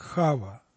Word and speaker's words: Хава: Дело в том, что в еврейском народе имя первой женщины прочитывается как Хава, то, что мы произносим как Ева.